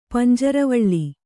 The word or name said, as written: ಪಂಜರವಳ್ಳಿ